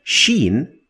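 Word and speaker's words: shin: Sheen is to be compared to the combination of SH in English. SH, not CH, like the sound at the beginning of the word sharp. Sharp. So sharp, sh sound, this is the sheen.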